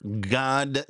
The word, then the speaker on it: Gad: There's a, at least it's a, it's an idea that's posited by Dr. God Sod, and that's God, G-A-D, not God, G-O-D. It's pronounced the same.